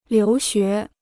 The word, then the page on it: 留学: liú xué